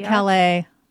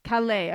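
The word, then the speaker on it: Caillat: Is it Kayat? – Oh, Colby Calais. – Calais. Okay, there you go.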